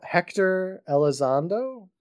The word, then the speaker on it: Hector Elizondo: We have Hector Elizondo.